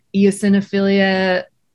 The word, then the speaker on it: eosinophilia: He went into eosinophilia. I'm not saying that right, but yes, you are.